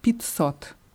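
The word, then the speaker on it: Pizzot (пятьсот): Also wird geschrieben Pet Sot, ausgesprochen Pizzot. Pizzot.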